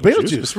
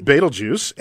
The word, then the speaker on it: Betelgeuse: Betelgeuse, really? – Betelgeuse, and that's how you pronounce it, Betelgeuse.